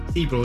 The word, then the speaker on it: Sibrel: Sibrel, is that how you pronounce it? – Bart Sibrel, not Bob Sibrel, but close enough.